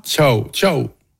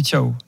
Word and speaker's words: Thiaw: Tiens, d'ailleurs, comment tu m'as prononcé Thiaw, le remplaçant de Kimmich ce soir? – Thiaw. – Ah, Thiaw, en allant, on dit Thiaw.